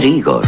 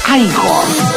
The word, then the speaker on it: Igor: Usted debe de ser Igor. – No, se pronuncia Igor.